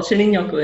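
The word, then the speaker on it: Shinrin-yoku: So first of all, Shinrin Yoku is the way the Japanese is pronounced. It's in English, it would be forest bathing.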